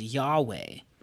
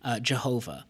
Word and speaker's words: Yahweh: And most scholars will pronounce it as Yahweh, not as Jehovah.